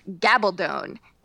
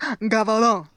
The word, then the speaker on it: Gabaldon: My name is pronounced Gabaldon. In Spanish, it's pronounced Gabaldon. – Oh boy, she's a real Claire. She's a real Sassenach. – There's more. It rhymes with stone.